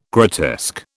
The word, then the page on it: grotesque: British English